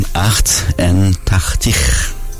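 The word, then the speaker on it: achtentachtig: خیلی فرخ به عنوان نمونه عرشت بگم عدد 88 رو میتونید چطوری تلفظ میکنند؟ جالب بود برای من. بهش میگن اخت ان تختیخ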